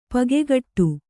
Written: ಪಕ್ಕುಗುಡು

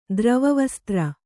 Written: ದ್ರವವಸ್ತ್ರ

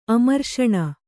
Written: ಅಮರ್ಷಣ

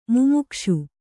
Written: ಮುಮುಕ್ಷು

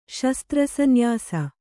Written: ಶಸ್ತ್ರಸನ್ಯಾಸ

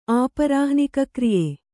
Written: ಆಪರಾಹ್ನಿಕಕ್ರಿಯೆ

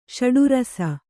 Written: ಷಡುರಸ

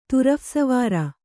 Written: ತುರಫ್‍ಸವಾರ

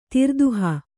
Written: ತಿರ್ದುಹ

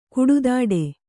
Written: ಕುಡುದಡಿ

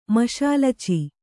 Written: ಮಷಾಲಚಿ

